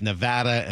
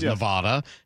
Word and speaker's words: Nevada: When I moved to the West Coast, I found out that it's Nevada and Oregon.